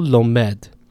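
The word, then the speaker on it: lomed (לומד): The stress there is on the final syllable, lomed.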